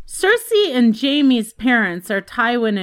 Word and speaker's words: Cersei: Cersei is pronounced like C-I-R-C-E Cersei, who was a nymph, enchantress, and sorceress in Greek mythology.